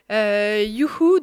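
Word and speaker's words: juchu: youhou, donc en tchèque, ça signifie hurrah parce que si je vous l'épelle, ça s'écrit pas comme nous on l'écrit en français, c'est J-U-C-H-U. u c h u et ce mot-là veut dire hurrah en tchèque.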